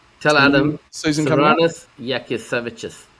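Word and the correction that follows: Jasikevičius: That's how you say it. – Yaskevicius, yeah. – Yeah, right. Yeah. Serranus Yaskevicius, yeah.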